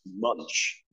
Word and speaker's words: Munch: I don't know that it's pronounced Munch. I thought it was pronounced Munch. But M-U-N-C-H. – Yeah. – I will spell it out. – M-U-N-C-H. Yeah. Like when you take a big bite out of a cookie, that's a big munch.